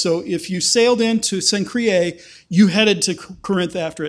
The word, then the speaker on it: Cenchreae: I wrote down the pronunciation because I knew I'd say it wrong. Cenchreae. What is with the Latins and vowels? You know, they string a bunch of vowels together and they expect people like me to be able to pronounce it.